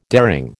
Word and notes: daring: American English

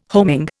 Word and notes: homing: Indian

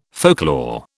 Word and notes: folklore: British English

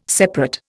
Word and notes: separate: Indian

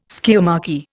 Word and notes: skiamachy: Indian

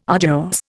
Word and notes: arduous: Indian

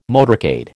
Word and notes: motorcade: American English

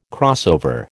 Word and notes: crossover: American English

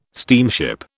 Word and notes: steamship: British English

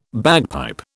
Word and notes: bagpipe: British English

